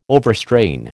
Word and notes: overstrain: American English

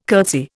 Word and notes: curtsy: Indian

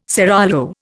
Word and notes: seraglio: Indian

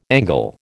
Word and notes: Angle: American English

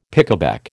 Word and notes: pickaback: American English

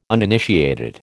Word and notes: uninitiated: American English

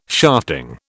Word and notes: shafting: British English

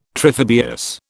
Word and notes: triphibious: British English

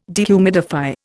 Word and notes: dehumidify: Indian

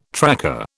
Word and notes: tracker: British English

